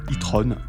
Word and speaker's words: e-tron: Ça s'écrit E, trait d'union, T-R-O-N. Si on le prononce à l'anglaise, ça donne e-tron. C'est assez classe, ça fait même un peu Avengers. Si on le prononce à la française, ça donne e-tron.